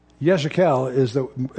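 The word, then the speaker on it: Yechezkel: Yeshekel is somewhat the way that Hebrews might pronounce what we call Ezekiel. He's one of the three captivity prophets.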